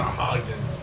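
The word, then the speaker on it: Sahuagin: The first thing we did with the I always call them Sahagin. – Dude, look at the bottom. There's Sahagin. There are. There's another syllable in there, dude. – Sahagin.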